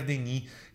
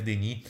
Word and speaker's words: Denis: eu acho que é a Claire Denis é a minha preferida. Se escreve Claire Denis, né, como se fosse Denis, mas na França a gente fala Claire Denis e três filmes dela,